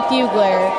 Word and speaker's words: Fugler: Through my Facebook, my makeup page is Makeup by Caitlin Fugler. And my last name is spelled F-U-G-L-E-R. – Okay. F-U-G-L-E-R.